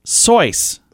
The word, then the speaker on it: Seuss: Seuss is his mother's maiden name, and their family pronounces it Sois. Like Sois. – Rhyming with voice.